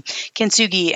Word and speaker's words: Kintsugi: Kintsugi.